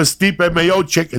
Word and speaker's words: Stipe Miocic: I'm coming for Stipe Miocic. It's over. I said it correctly.